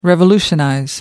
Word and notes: revolutionize: American English

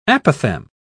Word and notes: apophthegm: British English

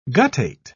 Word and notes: guttate: British English

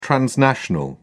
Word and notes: transnational: British English

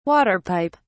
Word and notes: water pipe: British English

